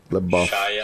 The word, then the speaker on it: LaBeouf: C'est LaBeouf.